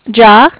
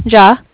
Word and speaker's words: Jia: Oh, my first name is J-I-A. – J-I-A. – Uh-huh. – J-I-A. What's how you pronounce that? – Ja. Ja. – Oh, okay, just like I. Okay. – Yeah, it's like J-A-H, basically. Or if you know the rapper Ja Rule, it's like that.